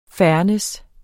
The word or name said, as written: fairness